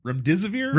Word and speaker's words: remdesivir: It's remdesivir.